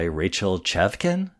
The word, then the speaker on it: Chavkin: It was written by composer-lyricist Dave Malloy and directed by Rachel Chavkin.